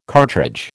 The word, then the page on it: cartridge: American English